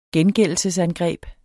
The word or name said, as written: gengældelsesangreb